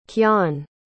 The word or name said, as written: kyan